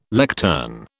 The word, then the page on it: lectern: British English